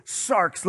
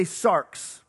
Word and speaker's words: sarx: The Greek word is sarx. Let me hear you say sarx. That's the way it's spelled, S-A-R-X.